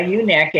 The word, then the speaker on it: ewe-neck: A ewe-neck, and it's spelled E-W-E like a sheep, because all sheep have ewe-neck.